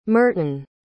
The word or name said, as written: merton